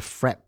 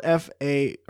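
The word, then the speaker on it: frappé: The frap. – It's spelled FRAPPE. – Yes. – And here in the States, we call that a frappe. – Yeah. – But it's pronounced just frap.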